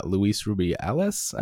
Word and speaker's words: Luis Rubiales: FIFA's disciplinary committee has opened disciplinary proceedings against Luis Rubiales,